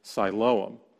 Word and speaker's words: Siloam: So now it has Greek declension, so it's pronounced Siloam.